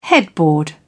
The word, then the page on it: headboard: British English